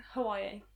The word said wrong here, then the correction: Huawei: Huawei P30 Pro.